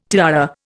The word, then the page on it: tiara: Indian